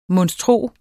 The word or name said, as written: monstro